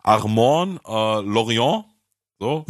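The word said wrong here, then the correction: Laurienté: Da ist ein Accent aigu auf dem E am Ende, deswegen wird es normalerweise lang gesprochen.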